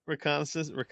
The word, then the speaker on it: reconnaissance: What did I call it? Recognizance. – That sounds right. – Reconnaissance. A reconnaissance mission. Right? – Nailed it.